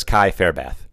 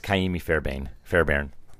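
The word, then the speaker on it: Ka'imi Fairbairn: I thought it was Kai Fairbath. – Yeah. – And it's Kaimi Fairbairn.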